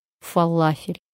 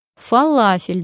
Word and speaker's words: фалафель: Фалафель, друзья мои, не фалафель. Здесь не работает правило, а мы помним, что в ударениях нет правил, но все же хотим обязательно применить какое-то правило. Что в заимствованных словах ударение ставится на последний слог. Запомним и будем заказывать правильно.